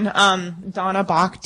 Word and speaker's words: Donna Bach: Donna Bach, D-O-N-N-A-B-A-C-H, formerly Elliot.